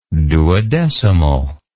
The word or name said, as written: duodecimally